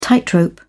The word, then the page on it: tightrope: British English